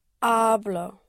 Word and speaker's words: hablo: Hablo. – Right. Yes, you push down on the first syllable here.